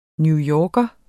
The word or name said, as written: newyorker